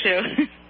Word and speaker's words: Shu: For our listener's sake, she spells her first name S-H-U and pronounces it like shoe on your foot, right? – That's right. It's Sue with an H.